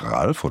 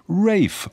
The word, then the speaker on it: Ralph: Aber was ich damit sagen möchte, der Name wird nicht Ralph oder Ralph, sondern Ralph ausgesprochen.